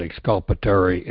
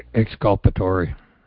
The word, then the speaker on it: exculpatory: What can I do to address the prosecutor leaving out exculpatory evidence and information to get a conviction? – Well, that's a crime.